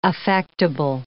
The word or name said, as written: affectable